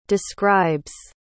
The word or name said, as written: describes